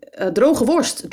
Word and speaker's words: droge worst: D-R-E-U-G-E. How do you spell that, Druge? – Droge worst, dried sausage. – Dried sausage mustard, yeah. – Or as we say in the local accent, droge worst.